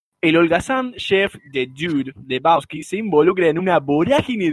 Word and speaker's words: Lebowski: Big Lebowski.